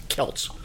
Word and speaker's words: Celts: But when you talk about these ancient people that were the ancestors of the Irish, it's Celts.